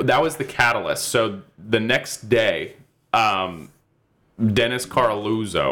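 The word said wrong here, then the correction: Coralluzzo: or whatever the fuck his name is. I keep mispronouncing his name wrong, but it's not on purpose. It's just that I don't care. And it's, I think, just it's like Coraluzzo. – Coraluzzo.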